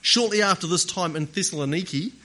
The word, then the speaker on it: Thessaloniki: In the north of Greece, the city of Thessaloniki is how they pronounce it these days, apparently.